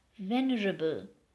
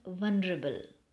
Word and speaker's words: venerable: Mark the pronunciation of the word, dear friends, venerable and not vulnerable. Yeah, you heard it correct. V-E-N-E-R-A-B-L-E.